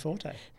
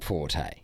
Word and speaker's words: Forte: To be fair, it's spelt Forte. – Yeah. – Exactly. – F-O-R-T-E. Yeah. I don't think it does the has the accent on the E.